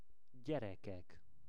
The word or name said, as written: gyerekek